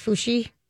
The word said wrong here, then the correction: Fauci: Dr. Fauci.